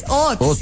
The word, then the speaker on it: haut: Es que es H-A-U-T. ¿Eso no es francés? Hot. Sí, eso es francés. Pues en el colegio me enseñaron que era francés, sí. – Hot. Sí, hot.